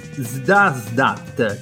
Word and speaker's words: Zdasdat: È il nuovo singolo, ahimè, del disco che adesso proverò a pronunciare, è Zdasdat, cioè Z-D-A-S-D-A-T. Facciamo con lo spelling perché è meglio.